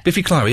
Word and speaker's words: Biffy Clyro: Biffy Clyro. Is that how you say it? – Yeah. – Biffy Clyro. Yeah. That's well done.